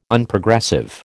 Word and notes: Unprogressive: American English

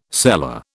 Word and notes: cellar: British English

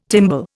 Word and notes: timbal: Indian

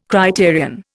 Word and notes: criterion: Indian

